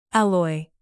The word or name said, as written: alloy